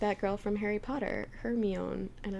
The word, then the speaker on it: Hermione: It's like in Harry Potter when everyone pronounced Hermione's name, like, Hermoine. I think there's a whole blog on ways people pronounced Hermione's name. – I did it Hermione until... – Actually, that's kind of pretty. I always said Hermoine, which is the ugliest sounding name. In the history of names.